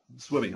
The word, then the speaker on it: SWMI: Is that pronounced Swimmy by any chance? – Well, why not? – Swimmy, okay. SWMI Consulting Group.